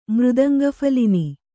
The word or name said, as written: ಮೃದಂಗಫಲಿನಿ